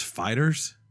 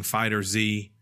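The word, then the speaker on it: FighterZ: It's pronounced fighters, which is crazy. Did we say that? – I refuse to say it like that. – But they're saying the confusion came because of the Z, that people are saying Fighter Z, Fighter Z, or Fighters.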